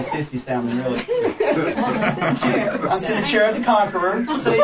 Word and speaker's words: Sennacherib: Now, if you look in 2 Chronicles chapter 32, there's this guy whose name, looks like Sennacherib, right? And that's how I always pronounce it, but I went and kind of did this study when I was preparing for the class, and that's not how you pronounce it. His name is pronounced Sennacherib. Think Sennacherib, and the emphasis is on the last syllable.